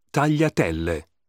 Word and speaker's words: tagliatelle: The combination GLI is pronounced gli. Tagliatelle.